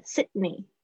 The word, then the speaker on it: Sydney: Sydney 那个 D 很轻的 Sydney